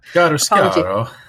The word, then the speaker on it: Chiaroscuro: Chiaroscuro. I can't pronounce that. – Chiaroscuro.